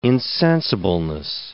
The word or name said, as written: insensibleness